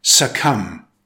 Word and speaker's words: succumb: Next, succumb. It's pronounced succumb with stress on the second syllable. The B is silent.